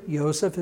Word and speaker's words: Yosef: Remember, Yosef is how you say Joseph.